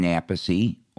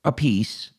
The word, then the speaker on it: Appice: Vinny Appice and Carmine Appice, or Appice, depending on which way you want to pronounce their last name, because each brother believes it's pronounced differently. One goes with Appice, and one goes with Appice.